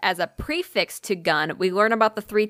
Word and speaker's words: gan: The word gun, but it's spelled G-A-N, so it's G-A-N, pronounced gun, is used as a collective noun.